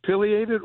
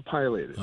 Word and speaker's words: pileated: The big woodpecker, is it pileated or pileated? – Oh, here we go. – It's actually either or both.